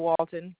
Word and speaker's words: Walton: Sure, it's Katrina Walton, KATRINA, and the last name is Walton, WALTON.